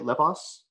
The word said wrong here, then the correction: Lesbos: Is that right, Adam? – Lesbos.